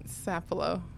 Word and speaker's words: Sapelo: Did I say it right? – Sapelo. – Sapelo. It's yeah. – Sapelo? – Yeah. – Sapelo. Oh, it's just like Sap. – Yes. – Sap. Sapelo. Okay.